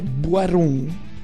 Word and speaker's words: Boiron: E infatti la Boiron, che è una importante società francese, che in realtà si scrive Boiron, ma che in realtà, ovvero si si dovrebbe chiamare Boiron, Perché c'est français.